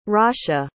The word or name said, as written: rasha